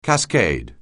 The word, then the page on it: cascade: anglais